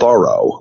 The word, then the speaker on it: Thoreau: Of course, like a lot of people, I read some in high school and kind of knew vaguely about Thoreau, which the experts tell me it's pronounced Thoreau, like Zorro, which I can't break the habit after my whole life of saying it the other way.